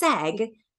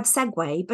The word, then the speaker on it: segue: And I thought that there was another secret word, S-E-G-U-E, that was pronounced seg and made something, meant something altogether different. But if you're saying from a topic of conversation to another topic of conversation, that is the word segue, but it's pronounced, I mean, it's spelt S-E-G-U-E.